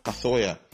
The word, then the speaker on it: Cazorla: It's Santi Cazorla, not Cazorla. Yeah, it can be hard to pronounce Cazorla sometimes because it's the Spanish custom of naming. But actually, it is Cazorla, not Cazorla. Not the way it is spelled, not Cazorla. It's Cazorla. Then another one, Memphis.